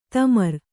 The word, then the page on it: ತಿಮುರು: Kannada